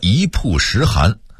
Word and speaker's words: 一曝十寒: 比如说一曝十寒，在这个词里这个字一定要念曝，晾晒的意思，事情曝光的时候读曝，胶片曝光的意思，所以要区别对待，事情曝光您念成曝光那就蛮拧了，考试答题一分也不会给。